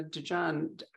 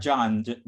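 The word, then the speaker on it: Djan: DeJohn. Am I pronouncing that correctly? – It's John. The D is silent.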